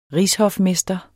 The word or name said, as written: rigshofmester